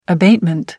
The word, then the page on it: abatement: American English